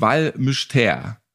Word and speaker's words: Val Mystère: Fünf Jahre als deutscher Arzt im kleinsten Spital der Schweiz, in einem der abgelegensten Orte, das Val Mystère. Habe ich das richtig ausgesprochen? Ja, das war perfekt.